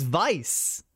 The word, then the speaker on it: Vice: It's Vice. – Vice. Vice. – But even though it's a W. Even though it's a W, they say Vice. – It's spelled with like German. They do say Vice.